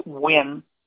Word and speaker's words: Nguyen: Another gentleman who's from Vietnam originally, his last name is pronounced Nguyen. It starts with an N.